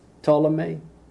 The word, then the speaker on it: Ptolemy: The countries that are at first belong to Ptolemy, because that P is silent, isn't it? – Yes, yeah, silent. Yeah, silent. Okay. – P-T-L-O-M-E-Y? – Uh-huh.